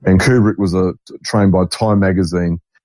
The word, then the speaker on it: Kubrick: Kubrick, pronounced Kubrick, born July Kubrick.